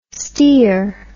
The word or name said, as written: stear